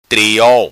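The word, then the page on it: Triol: Macanese Patuá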